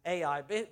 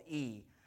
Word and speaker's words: Ai: heard how Joshua had captured Ai. Basically, I'm pronouncing that wrong just so that you know that it's called E. But we're not going to do that. I like Ai.